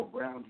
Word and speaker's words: Brown: Yes, this is Errol Brown here.